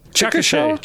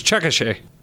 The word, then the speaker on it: Chickasha: A family in a place called Chickasha. Is that how you say it? – Chickasha. Anyway. I saw this news report earlier. It's Chickasha.